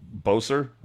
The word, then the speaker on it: Boeser: And the kid that I'm really excited about, of course, is Brock Boeser. Am I saying that right? – Yes, sir.